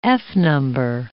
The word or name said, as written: f-number